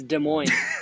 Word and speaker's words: Des Moines: Pronounced that, because it's French. I'm pretty sure it's French. Yeah. – I took French in high school, man. It's Des Moines.